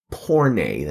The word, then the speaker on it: porne: First things first, the word here that is translated as prostitute or harlot or the WH word is the Greek word porne. That's P-O-R-N-E in English, or porne with a kind of an accent on the end.